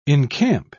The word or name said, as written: encamp